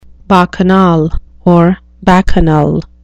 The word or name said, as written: bacchanal